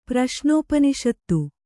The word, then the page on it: ಪ್ರಶ್ನೋಪನಿಷತ್ತು: praśnōpaniṣattu